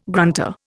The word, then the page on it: grunter: Indian